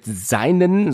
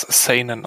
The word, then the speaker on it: Seinen: Also mit seinen, so steht es hier, wie wird das nochmal ausgesprochen? Das ist mit Sicherheit nicht korrekt gesagt, oder? – Soweit ich weiß, spricht man das Seinen aus.